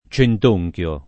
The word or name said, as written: centonchio